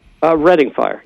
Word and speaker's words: Redding: And is it pronounced Redding or Reading? – Redding fire. Yeah.